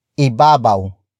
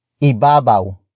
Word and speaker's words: ibabaw: But before that, we have ibabaw. Ibabaw.